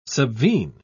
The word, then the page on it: subvene: British English